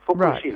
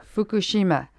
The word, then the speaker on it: Fukushima: You put in equal emphasis, on each syllable. Fukushima. – Right. Fukushima.